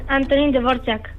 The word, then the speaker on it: Antonín Dvořák: Antonín Dvořák.